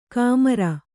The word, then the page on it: ಕಾಮರ: kāmara